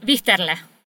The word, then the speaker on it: Wichterle: Wichterle, is that how he pronounces him? Otto Wichterle? – Otto Wichterle. Wichterle.